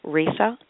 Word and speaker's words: Risa: Risa, R-I-S-A, at Risa.